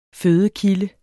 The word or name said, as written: fødekilde